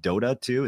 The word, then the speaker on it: Dota 2: League of Legends, or is it Dota 2? Is that the proper way to pronounce it? – Okay. Yep, you said it right.